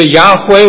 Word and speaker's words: Yahweh: Pronounce it either Yahweh or Jehovah.